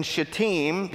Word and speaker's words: Shittim: In just a moment, we're going to read that the Israelite spies are sent from their camp in Shittim.